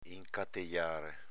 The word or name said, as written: incatejare